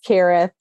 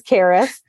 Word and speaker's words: Kerith: So I very politely interrupted and said, actually, my name is Kerith. And she was like, what? And I was like, my name is Kerith.